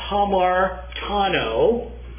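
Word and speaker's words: hamartano (ἁμαρτάνω): This thing, which in the Greek looks like that, is pronounced hamartano, takes a number of different forms in the Greek language, is the word that is most often translated sin.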